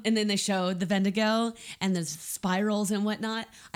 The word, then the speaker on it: Wendigo: It's it's spelled Wendigo, but I think it's pronounced Vendigo. – Vendigo.